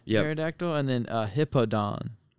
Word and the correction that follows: Hippowdon: Oh, Hippowdon.